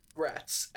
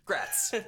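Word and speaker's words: Graz'zt: There's just a picture on the results that had the pronunciation pronounced Grats, as in congrats. – Grats.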